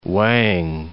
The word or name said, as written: Wang